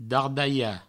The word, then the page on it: dardalhar: Provençal; dardaia